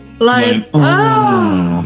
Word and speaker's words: Lion-O: Putting forward a motion, Lion-O, the O should be pronounced like an orgasm. – Lion-O.